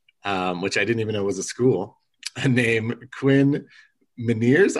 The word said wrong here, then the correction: Meinerz: I don't know if I'm saying that right. – Yeah, I think it's Quinn Meinerz. – Meinerz. That makes more sense.